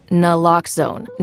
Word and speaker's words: Naloxone: For the record, it's Naloxone.